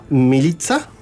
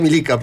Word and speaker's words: Milica: Que se escribe Milica, y yo le decía Milica, pensando que se decía así.